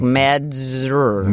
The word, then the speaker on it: Mazdzer: It's Mazdzer, so it's like Mazder.